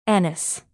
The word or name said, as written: anise